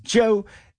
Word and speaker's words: Djo: It's spelled D-J-O, but it's pronounced Joe.